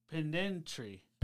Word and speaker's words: pedantry: Panteri syndrome? – Pendant. Pedantry. – Pedantry syndrome.